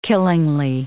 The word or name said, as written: killingly